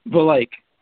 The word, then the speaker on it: Valeich: Did I pronounce that right? – Valeich. It's a tough one.